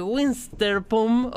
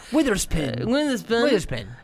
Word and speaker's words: Witherspoon: Es una película que tiene a Reese Winsterpum o lo como se pronuncia? porque es imposible. – Witherspoon.